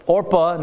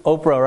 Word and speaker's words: Orpah: That's Orpah, not, I know you're thinking, right? You're thinking Oprah, right? Right? You get a car, you get a car. No. This is I almost said it wrong too. It's Orpah.